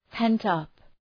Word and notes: pent up: ,pent’ʌp

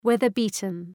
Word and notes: weather beaten: ‘weðər,bi:tən

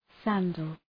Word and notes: sandal: ‘sændəl

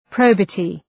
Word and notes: probity: 'prəʋbətı

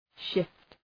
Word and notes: shift: ʃıft

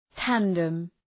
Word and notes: tandem: ‘tændəm

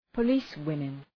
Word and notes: policewomen: pə’li:s,wımın

